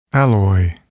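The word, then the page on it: alloy: noun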